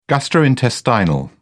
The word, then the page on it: gastrointestinal: British English